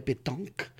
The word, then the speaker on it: pétanque: We play pétanque, which is a version of a bocce ball, but we play those balls.